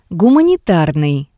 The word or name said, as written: Gumanitarnii